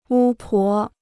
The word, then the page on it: 巫婆: wū pó